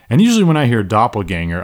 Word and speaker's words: doppelganger: Our first word is doppelganger. It is spelled D-O-P-P-E-L-G-A-N-G-E-R. I believe it's German, doppelganger. D-O-P-P-E-L-G-A-N-G-E-R, doppelganger.